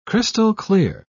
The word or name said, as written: crystal-clear